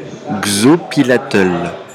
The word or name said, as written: xopilatl